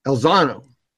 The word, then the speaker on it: Elizondo: The game has changed because it is, kind of like with, Elizondo they brought in, Yolander, was it Elizondo? – I'm saying it wrong, but Elizondo. Yeah, you said it right.